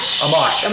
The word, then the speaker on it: Amash: Let's talk about Justin Amish. Am I saying that right? – Amash. Well, none of us Amish. – Are saying it right.